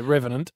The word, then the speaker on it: Revenant: Well, we're hard to go past the remnant. How do you say that? Revenant? – Write that down. With a when he was at Jesse from Point Cook, was it? – Yeah, Revenant.